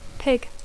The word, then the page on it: pig: English